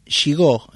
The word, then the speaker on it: Gigoux: y puede encontrarlo en Instagram, Esteban Gigó, se escribe Gigoux, ¿no?